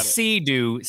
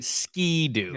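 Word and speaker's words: Ski-Doo: Same product. SKI-dash. – Correct. Do. Yeah. Not sea do. Ski do.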